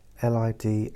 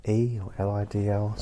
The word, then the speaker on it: Lidl: And for years and years and years, the television adverts would call themselves Lidl, pronounce it Lidl, and nobody else did. Just the TV adverts. Nobody going there would say, I see you outside Lidl at 3:30. Nowhere in a conversation would anyone say so. Where did you get these lovely scones from? Oh, I got them from Lidl. No, no one ever said that. They said Lidl. Lidl, because that's how it's spelt and that's how we pronounced it.